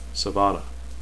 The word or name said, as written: sevanna